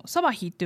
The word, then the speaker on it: サバヒ: まああのまたあのサバヒというのはですねそのまま台湾語の発音なんです。